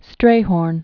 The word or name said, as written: Strayhorn